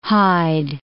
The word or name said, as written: Hyde